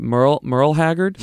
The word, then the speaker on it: Merle Haggard: Is it Merle Haggard? – Merle, yeah.